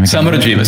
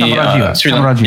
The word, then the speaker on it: Samarajiva: Samarajiva. So it's phonetic. So it's actually only five characters in our language. So okay. Samarajiva. You can read almost any Samarajiva.